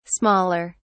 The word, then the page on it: smaller: British English